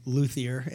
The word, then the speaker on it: luthier: So apparently I didn't know there was a silent H in luthier and it's luthier, but I guess there's a grammar lesson for all of us in this today, so we get something out of it.